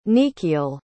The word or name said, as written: nequiel